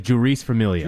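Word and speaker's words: Jeurys Familia: Juris? Is that how you pronounce it? – I think it's Juris Familia.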